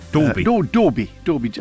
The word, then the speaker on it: Dalby: It's actually spelt D-A-L-B-Y. Many people call it Dalby, as in Dalby systems they used to have. It's actually pronounced on the island Dalby. – Dorby, like D-O-R-B-Y, Dorby.